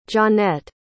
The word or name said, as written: johnette